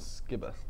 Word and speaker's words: Skiba: How do you pronounce that? – Skiba.